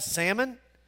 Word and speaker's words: Salmon: You look at that and you go, she got a salmon? Pronounce it for you. Salmon.